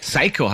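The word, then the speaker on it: saikō: Ja, übrigens, Psycho hast geil. – Psycho? Ah! – Okay. – Ja, so wenn man auf Englisch Psycho aussprechen würde, aber es ist S-A-I-K-O mit einem Strich drüber. – Psycho.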